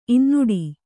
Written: ಇನ್ನುಡಿ

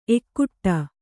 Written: ಎಕ್ಕಡಿ

